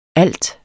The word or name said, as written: alt